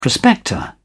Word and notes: prospector: British English

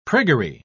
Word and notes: priggery: British English

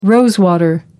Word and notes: rose water: American English

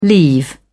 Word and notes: leave off: British English

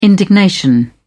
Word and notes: indignation: British English

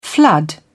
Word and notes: flood: British English

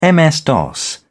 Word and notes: ms-dos: British English

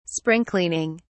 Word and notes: spring cleaning: British English

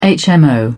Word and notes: hmo: British English

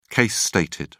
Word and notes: case stated: British English

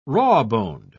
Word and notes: rawboned: British English

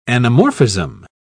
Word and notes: anamorphism: British English

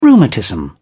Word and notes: rheumatism: British English

